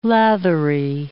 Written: lathery

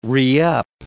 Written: re-up

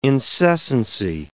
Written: incessancy

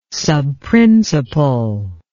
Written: subprincipal

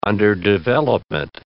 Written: underdevelopment

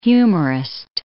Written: humorist

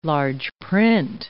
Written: large-print

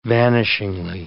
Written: vanishingly

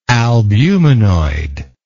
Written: albuminoid

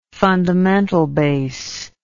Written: fundamental bass

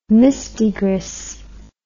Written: mistigris

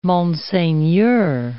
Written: monseigneur

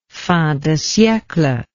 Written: fin de siecle

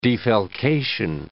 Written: defalcation

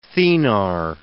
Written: thenar

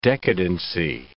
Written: decadency

